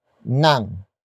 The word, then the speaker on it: ng: Up next we have nang. Nang. Nang. Nang is spelled as ng.